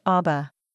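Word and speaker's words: Abba (אבא): The Hebrew word for father is pronounced Abba.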